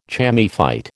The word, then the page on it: chamaephyte: American English